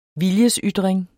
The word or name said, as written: viljesytring